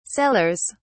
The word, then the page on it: cellars: British English